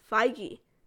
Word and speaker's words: Feige: Kevin Feige. That is how you pronounce his name. – Okay. – Not Feige, not Feige. It's Feige.